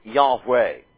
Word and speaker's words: Yahweh: Yahweh. And, or Yahweh may be the better pronunciation of it. But Yahweh, more commonly, or Yahweh, certainly not Jehovah. Alright? Not that.